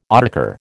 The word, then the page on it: autocar: American English